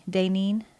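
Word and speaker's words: Daynene: It's D-A-Y-N-E-N-E. Dainene. – Huh. Like Nadine, pretty. But Dainene.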